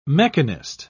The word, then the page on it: mechanist: British English